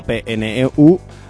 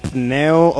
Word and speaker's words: Pneu: Bueno, pues hasta aquí este concierto de la banda francesa Pneu o Pneu, que se escribe P-N-E-U,